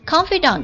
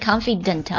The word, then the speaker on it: confidant: confidant 这个单词不要读成 confident 啊，它是 confidant，它是一个法语词，有点类似于，然后它的意思是知己或者密友至交的意思啊。